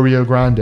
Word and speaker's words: Rio Grande: So that's 6th and Rio Grande. – That's right. – Or Rio Grande, as we see on the border. – That's right. – Yeah, every place else will pronounce it Rio Grande.